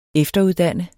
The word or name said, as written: efteruddanne